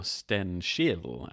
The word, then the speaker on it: Stenkil: So enter Stenkil, or Stenskil, as he was probably more properly pronounced in Swedish and back in the day.